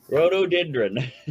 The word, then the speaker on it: Rhododendron: Rhododendron.